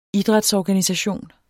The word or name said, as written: idrætsorganisation